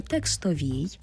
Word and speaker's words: текстовій: І якщо ви подивитесь на табличку у текстовій, пам'ятаємо й про правильний наголос, саме текстовій,